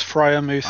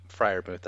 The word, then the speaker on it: Freiermuth: It's Freiermuth. – I don't know. – Yep, Freiermuth. That's what I have. – Thank you. Yeah, Pat Freiermuth.